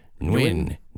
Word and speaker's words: Nguyen: Nguyen. – Nguyen. It's spelled N-G-U-Y-E-N. Nguyen. – Wow. – And it's like one syllable, but it's like Nguyen.